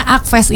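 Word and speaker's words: ACFFest: Tadi tuh saya sempat ngobrol juga sahabat suara ya, karena Akfest ini secara ejaan tuh A-C-F-Fest, bacanya Akfest, ternyata itu akronim ya Mas ya? – Iya betul.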